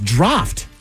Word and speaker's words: draught: Drought. – D-R-A-U-G-H-T. – Yeah. Why is that hard to say? – Drought? – Actually, no, it's draft. – Draft. So there's a silent F. – D-R-A-U-G-H-T.